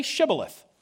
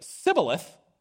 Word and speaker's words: Shibboleth: They said, "All right, say Shibboleth." If they said Sibboleth, because he could not pronounce the word correctly, they seized him and killed him at the fords of the Jordan.